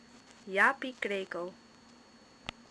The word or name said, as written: Japie Krekel